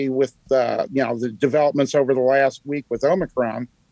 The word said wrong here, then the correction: Omicron: however we pronounce it, it's there's no N in Omicron. It's Omicron. No N.